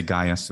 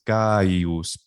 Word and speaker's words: Gaius: Gaius is actually three syllables. We say Gaius, of course, in English, two syllables. Bum bum Gaius. But in Latin, it's Gaius.